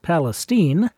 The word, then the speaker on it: Palestine: It's spelled Palestine, but it's pronounced Palestine.